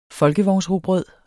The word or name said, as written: folkevognsrugbrød